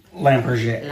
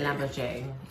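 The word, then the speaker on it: Lampe Berger: It is spelled L-A-M-P-E mm-hmm. space B-E-R-G-E-R. – Yes. – Pronounced Lamberger. – Lamberger.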